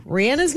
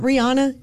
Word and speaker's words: Rihanna: Rihanna's name is not Rihanna. Her name is Rihanna. Here she is telling Ellen, back in the day when Ellen had her talk show, the correct way to pronounce her name. – Now, I heard, is it Rihanna or Rihanna? – Rihanna. I say Rihanna, but Rihanna is still okay.